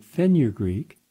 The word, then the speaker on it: fenugreek: Well, a study from the University of Agricultural Sciences in India shows that fenugreek helps those. Fen, F-E-N-U, Greek, G-R-E-E-K, three syllables, fenugreek.